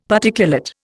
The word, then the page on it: Particulate: Indian